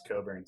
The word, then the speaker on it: Cockburn: He's probably a guy who hates that it's pronounced Coburn too.